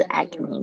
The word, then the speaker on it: acne: 那底下这两个呢，也是就是说，我们去去买买东西，可能会需要有治疗什么样的状况？For 什么东西？For acne，注意一下 acne，这个是 A C 是 ac，ac，ne 啊，注意一下这个，这是 N E 可以讲你的声音，好，比较特别的发音，acne，acne，OK，接下来是。也是很讨厌的哦，就是 acne 的前身就是 blackhead，所以呢，在这变成一个字 blackhead，blackhead，好，所以你的 ck 呢稍微轻一点。